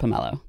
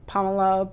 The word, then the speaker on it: pomelo: I happen to pronounce it pomelo. – I grew up saying pomelo, but pomelo, that people say it different ways.